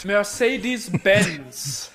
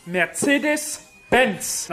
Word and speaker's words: Mercedes-Benz: Next, Mercedes-Benz. this makes me sick. The name is pronounced Mercedes-Benz.